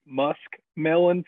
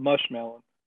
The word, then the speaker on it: musk melon: So in the mountains, we we mispronounce a lot of things with our own slang, and we call it mushmelon.